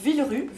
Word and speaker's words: Villerupt: Villerupt, voilà, on ne prononce ni le P ni le T, Voilà. – pourtant il y en a à la fin du mot.